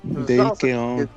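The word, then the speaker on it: Keown: ¿Keon? – Es Dale, se, que escribe D-A-L-E y K-N-O-W, ¿verdad? Sí. – Dale, algo así. – Dale Keon.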